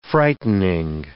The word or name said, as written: frightening